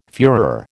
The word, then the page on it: fuehrer: American English